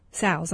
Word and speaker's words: sows: 60 to 70% of sows are confirmed in crates during pregnancy. – Sows. – Sows, I'm sorry. – That's okay. That's okay. – Sows, it rhymes with cows.